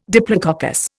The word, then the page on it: diplococcus: Indian